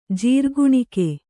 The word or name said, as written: ಜೀರ್ಗುಣಿಕೆ